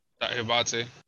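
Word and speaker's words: Diabaté: I actually do like I don't know how to pronounce his name. Diabete. – Diabate. – Diabate. – Diabete.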